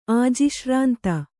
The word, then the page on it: ಆಜಿಶ್ರಾಂತ: Kannada